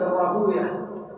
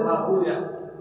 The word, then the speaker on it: راهويه: راهويه المحدثون يقولون راهويه نفطوية سيبوية ويغضبون إذا قرأها ويه نفطة ويه لا يريد هكذا ينطقها المحدث راهوية